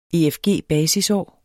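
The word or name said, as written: efg-basisår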